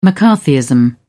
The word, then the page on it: mccarthyism: British English